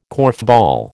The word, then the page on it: korfball: American English